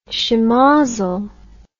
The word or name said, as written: schemozzle